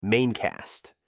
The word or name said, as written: main cast